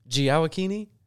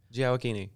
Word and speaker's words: Gioacchini: Are we getting the name right? Gioacchini. Gioacchini. No, I started to say it wrong, and I kind of tried to like swerve it back.